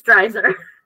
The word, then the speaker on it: Dreiser: Is it okay? You like made it French. – Dreiser? – It's Dreiser. – Dreiser. – You said like Dreiser or something.